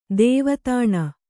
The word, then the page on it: ಧವಾದಿನಿ: kannada